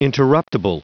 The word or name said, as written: interruptible